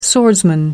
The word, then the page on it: swordsman: American English